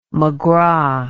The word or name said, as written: mcgraw